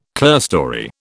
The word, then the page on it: clearstory: British English